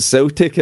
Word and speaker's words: Celtic: So because, why don't you pronounce it Celtic? They're in the home of the Celts. – I don't know. It's a weird thing. We pronounce it Celtic, and that's where a lot of Americans get it wrong. They go, yeah, I was going to see Celtic, or, you know, they get, because you would think logically it would be Celtic, but for some reason it's pronounced Celtic. I just get no idea why. Always has been.